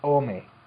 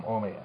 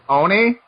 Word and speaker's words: Omi: It's Omi. This is an email from Omi. And Oni? – Omi. – Oni means demon in Japanese, but right, yeah, okay, yeah. Continue. – No, he's phonetically wrote it. His name's spelled, I would have said Ami, A-M-I, but he says his name's pronounced Omi, so it's Omi.